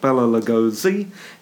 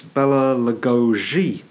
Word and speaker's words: Bela Lugosi: Oh, and apologies to any Hungarians listening, especially George in Drumheller, because it's, of course, not Bela Lugosi, it's Bela Lugosi. Apparently, that's a Hungarian pronunciation.